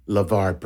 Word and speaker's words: LeVar: And by the way, Neil, I just want to say before we go any further, you're saying his name wrong. It is National Treasure, LeVar Burton.